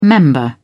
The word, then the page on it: member: British English